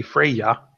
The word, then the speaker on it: Freyja: Freyha? What were you going to say? – Freyja? I guess it'd be Freyja, because it's Norse and Swedish. It's not Spanish.